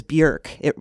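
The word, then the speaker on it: Björk: And it is, I will also tell you, it is Bjork. She will say it rhymes with jerk.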